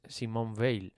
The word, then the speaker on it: Simone Veil: pues mayonesa cigarro es Simone Veil, en realidad, que es una...